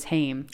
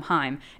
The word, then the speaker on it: Haim: And then the third person in this trio was Haim. Haim? – Haim. I said it wrong. Um, Haim.